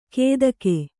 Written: ಕೇದಗೆ

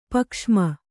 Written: ಪಕ್ಷ್ಮ

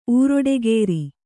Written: ಊರೊಡೆಗೇರಿ

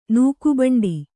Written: ನೂಕುಬಂಡಿ

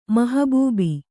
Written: ಮಹಬೂಬಿ

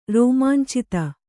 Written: ರೋಮಾಂಚಿತ

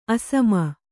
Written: ಅಸ್ಸಾಮಿ

